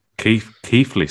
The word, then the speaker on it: Keighley: It was originally in Victoria Hall in Keighley. Is that how you pronounce it? Or Keighley? I'm not sure. – Keighley, sorry.